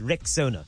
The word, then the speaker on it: Rexona: It's pronounced Rexona.